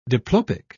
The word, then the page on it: diplopic: British English